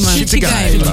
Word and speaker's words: Schiltigheim: Schiltigheim.